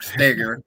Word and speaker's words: Steger: Jack Steger, even though it's spelled S-T-E-G-E-R.